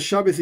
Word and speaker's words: Shabes: ¿Por qué se pronuncia Shabes y Toira? Pregunta Felipe. Porque yo tengo una pronunciación ashkenazí. Y dentro de la pronunciación ashkenazí, hay un video en el canal sobre este tema.